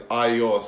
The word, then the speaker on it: Ayo: So the right way to say it is Ayo. So think of like I am going somewhere, that I, and then you're like, I'm giving you your bag.